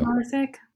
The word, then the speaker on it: Maersk: Maersk. It's a M-A-E-R-S-K.